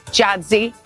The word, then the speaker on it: Giadzy: Giadzy.